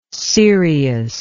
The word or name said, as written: cereous